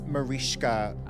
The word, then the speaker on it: Mariska: It's Mariska.